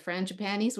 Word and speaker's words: Frangipanis: And the Frangipanis were out. Did I pronounce that right? – Yes, I did.